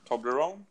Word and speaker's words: Toblerone: Do you say it like that? Toblerone. – Yeah.